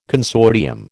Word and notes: consortium: American English

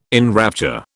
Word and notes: enrapture: British English

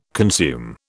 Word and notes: consume: British English

